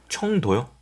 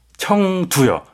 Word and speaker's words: 청두: 청도요? – 청두요.